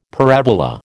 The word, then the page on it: parabola: American English